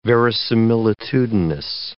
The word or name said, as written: verisimilitudinous